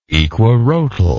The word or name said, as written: equirotal